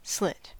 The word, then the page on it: slit: American English